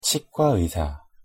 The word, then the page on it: 치과의사: Korean